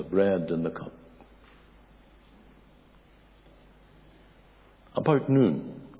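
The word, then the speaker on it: noon (νῦν): In the Greek, that is N-U-N, and it is pronounced noon. Not non, but noon.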